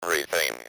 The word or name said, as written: everything